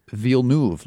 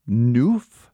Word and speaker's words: Villeneuve: Chris Fivey said, pretty sure it's Villeneuve, lads. He spells it V-E-E-L-N-O-O-V. So a vote for Villeneuve. – The long E in that one. Dakota Arsenault, also on Twitter, says, this is from a Canuck, Denise. Ville like the village. Noof. And then she has rhymes with hoof. So she spells it N-U-U-V.